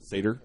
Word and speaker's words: satyr: Did PP ever confirm that the satire or the... – Satyr? – The Satyr Shyman.